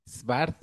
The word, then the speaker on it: Svart: Svart. Sí, Svart, así, sueco.